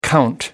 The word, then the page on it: count down: British English